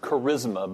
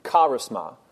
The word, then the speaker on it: charisma: We might pronounce it charisma, but it's really charisma.